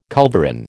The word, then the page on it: culverin: American English